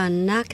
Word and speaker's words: Knock: 显然是中间 C-H 没有发音 Y-A-C-H-T, 但是要念成 yacht, 在英文里面有很多类似的情形，某些字母是不发音的，比如说我们讲数目字 8 叫做 8, E-I-G-H-T, 但是那个 G-H 就没有发音。像我们唱了一首歌叫 Knock Three Times, 敲三下，那个 Knock, K-N-O-C-K, 那个 K 也是没有发音。像我们讲小羊，小羊叫 Lamb, L-A-M-B, 那个 B 也没有发音。